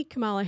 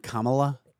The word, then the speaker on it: Kamala: Did you see Kamala Harris, what her birthday or Christmas present was? – Who, you mean Kamala? – Did I say it wrong? – Yes. – Kamala.